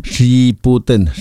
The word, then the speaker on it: 西布顿: 西布顿，西西，太难了。 – 就是一个阿美族的发音叫西布顿，